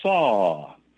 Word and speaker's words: sau: You inhale and say the word Hong, H-O-N-G, just like in Hong Kong. Then when you exhale, you say the word saw, like a saw, except it's spelled S-A-U.